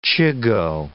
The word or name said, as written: chigoe